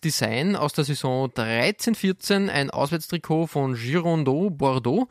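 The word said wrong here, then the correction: Girondins: Und Girondin.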